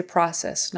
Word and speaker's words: process: Um, typically we say process, not process. Ah, ah. That would be um, a short O sound.